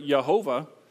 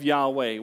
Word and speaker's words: Yahweh: The resulted misguided pronunciation of the name YHWH as a three-syllable word, Jehovah, continued in our English Bible translations until the early 20th century. Evidence from Greek usage in the Christian era pointed to the two-syllable pronunciation of Yahweh, which is why we have the confusion and sometimes doublespeak of Yahweh and Jehovah.